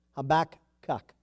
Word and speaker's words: Habakkuk: But God, you are following what we learned right here, because God and Habakkuk, I'm getting that right. That's how you pronounce it. Habakkuk.